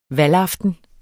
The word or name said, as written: valgaften